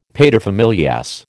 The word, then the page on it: paterfamilias: American English